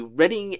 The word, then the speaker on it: Reading: This year's iteration of the Reading and Leeds Music Festival.